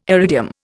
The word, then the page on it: iridium: Indian